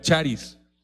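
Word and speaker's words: charis (χάρις): Y algo muy interesante es cómo la palabra don en el Nuevo Testamento está relacionada con una palabra griega que se llama, o que se pronuncia, charis.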